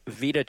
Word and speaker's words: Vita: The endless Vita Chamber. Rhymes with PETA.